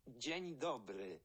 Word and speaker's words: Dzień dobry: Dzień dobry.